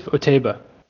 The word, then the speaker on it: Otaiba: named um, Otaibi. Otaibi, what? How do you say his name? – Yusuf Otaiba.